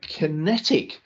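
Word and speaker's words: QinetiQ: Kinetic, and this is spelt, just so if you're looking it up as an investor, it's Q-I-N-E-T-I-Q, and ticker is QQ, 2.2 billion market cap. And the shares in Kinetic, and this is one that Andrew and I were looking at defense a couple of months ago.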